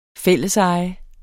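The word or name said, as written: fællesejet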